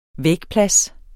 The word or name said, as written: vægplads